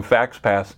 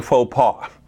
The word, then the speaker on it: faux pas: And the ironic thing is that in the midst of being afraid of committing a FaxPass, she was committing a faux pas. And that's actually how you pronounce it.